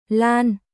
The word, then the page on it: Lan: Vietnamese